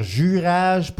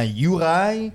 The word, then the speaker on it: Juraj: Je viens d'arrêter de dire Jurage pour dire finalement Juraj.